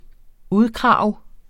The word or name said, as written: udkrage